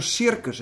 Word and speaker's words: circus: Of als je nou circus hebt. De eerste C, die spreek je uit als een... – S gelijk? – Als een S. En de tweede C spreek je uit als een K.